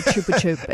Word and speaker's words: Chupa Chup: You know, I'm going to have to then explain and be like, it's actually chupa chup.